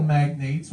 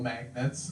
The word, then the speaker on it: magnates: After convincing a couple of oil magnates, which I also just realized is not pronounced oil magnets.